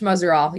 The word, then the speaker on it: Muzzerall: You know, and that's Coach Nadine Muzzerall. Am I getting close to pronouncing that correctly? – Yeah. Coach Muzzerall. Yep.